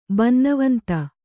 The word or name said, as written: ಬನ್ನವಂತ